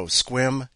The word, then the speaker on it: Sequim: Oh, and those of you who don't know, Squim is spelled S-E-Q-U-I-M.